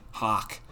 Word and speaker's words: Hok: They're called the Altai Hawk, Altaihok. H-O-K, pronounced like a hawk that flies, obviously.